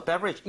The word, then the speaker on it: beverage: Okay, so beverage. 이것도 발음을 한번 해볼게요. 앞에 BE 쪽에 강세를 주고 따라해주세요. Beverage. Beverage.